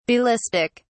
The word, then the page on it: Ballistic: British English